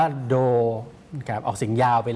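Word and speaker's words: โด ครับออกเสียงย า วไป (道路): โดโรครับออกเสียงช้าๆจะออกเสียงว่าโดโรโดโรครับโดอุนะครับตรงโดอุตัวเนี้ยออกเสียงเร็วครับออกเสียงว่าโดครับออกเสียงยาวไป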